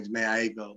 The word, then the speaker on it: Jhené Aiko: But I do, I'm a big fan of I don't know how to say her name, Jhené Aiko.